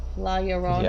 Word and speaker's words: La Llorona: La, because it's a double L and that's a Y, isn't it? La Llorona.